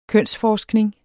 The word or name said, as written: kønsforskning